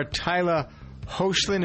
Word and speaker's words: Hoechlin: Former Teen Wolf star Tyler Hoechlin, is that how you pronounce his name? Anybody know this guy? – I believe that is right.